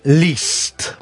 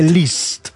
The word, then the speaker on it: Liszt: Sarebbe Liszt, molti sbagliano, però io sono andato a controllare la lingua ungherese, effettivamente è Liszt, molto semplicemente.